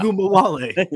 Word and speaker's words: Ogunbowale: Ogunbowale.